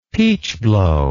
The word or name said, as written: peachblow